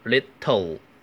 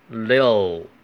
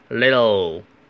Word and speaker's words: little: 这一句的关键在于 little 的发音，在美语里面，这个 t 是不爆破的，不要读成 little，读成 little，直接把舌尖顶在上齿的根部，直接就发边音呃 little。